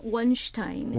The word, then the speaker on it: Wonstein: I always call him Wonstein. – Wonstein, yeah. – Which is the Korean pronunciation.